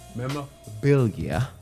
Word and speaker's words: memorabilia: She wants all her memorabilia. She wants all that back. – One syllable missing. – Huh? – Only missed one syllable. – Memorabilia. – There you go.